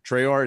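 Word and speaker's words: Traoré: Traore, is that how you say it? – That's correct.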